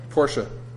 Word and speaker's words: Porsche: Porsche. There are no silent E's in German. Not a one.